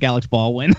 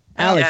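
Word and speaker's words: Alec Baldwin: Oh, Ron doesn't like Alex Baldwin. – oh, Alex, Alex, Baldwin. – Alec yeah, Baldwin.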